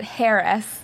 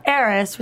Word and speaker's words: heiress: She pronounces it Harris. – And he says, no, it's heiress, which is how he then ends up being George Harris.